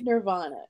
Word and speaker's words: Nirvana: am I am I pronouncing this correctly? – Nirvana. Yes.